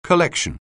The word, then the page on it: collection: anglais